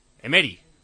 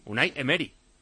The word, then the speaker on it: Emery: Y dice otro que nos enrocamos mucho con el tema del nombre de Faisal o como se diga, pero Emery no lleva tilde y sería Emery. Emery. – Es verdad. – Claro, Emery. – Emery. – Unai Emery.